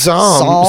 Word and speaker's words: psalm: How do you say that word? – I think it's just psalm. – Psalm. – Yeah. – Why is that P there? – It's annoying.